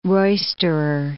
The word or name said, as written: roisterer